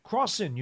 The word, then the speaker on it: Crossin: And then the other thing is that, you know, from the cornerback position, you know, is it Crawfin or Crawfim with an M or an N? – Crossin, you mean, the guy they just brought in? – Yeah, Crawfin. Okay. – Crossin with an S.